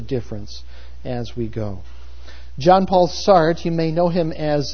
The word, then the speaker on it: Sartre: And Sartre, as his name is pronounced, embodied what it was to be left, to be socialist, and to be politically liberal.